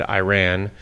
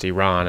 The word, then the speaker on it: Iran: Tyler Adams said Iran. And, you know, he prefaced his question by saying, first of all, it's pronounced Iran, and let's get that straight once and for all.